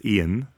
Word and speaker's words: Iain: a big thank you to Ian, even though I think it's a Welsh Ian, which is Iain, but I think it's pronounced Ian, or correct Welsh people, correct me if I'm wrong, but I think it's I-A-I-N.